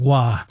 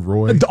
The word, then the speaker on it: Roy: And then Patrick Waugh. We're talking about hockey, not the NBA. Larry Walker going into the Hall of Fame this year. But Patrick Roy. – Didn't someone famously mispronounce him as Patrick Roy? – All of us.